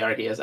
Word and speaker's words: Arceus: I think it is actually Arceus. I say it wrong.